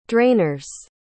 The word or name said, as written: drainers